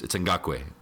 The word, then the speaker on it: Ngakoue: Instead, someone asked me very kindly, "Excuse me, sir, how do you pronounce the name on the back of that jersey?" And I thought they were like trolling me or something. I was like, "Oh, it's Ngakoue."